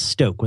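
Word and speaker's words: Stok: And then they got this thing called Stoke with a long O, and it's black.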